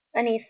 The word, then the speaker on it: Anicia: Anicia. – Can you spell that for me? – Yes, A-N-I-C-I-A. – C-I-A? – Mm-hmm.